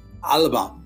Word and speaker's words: Alba: So he basically has launched a new party, or he's created a new party, the Alaba Party. To pronounce it. – Well done. – Thanks very much. I've been watching lots of BBC Alba idents just to try and get the pronunciation bang on.